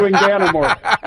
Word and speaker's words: Inganamort: You're going to have to do it phonetically. I mean, anything it rhymes with is not anything I want to say. So let's just stick to Enganimore.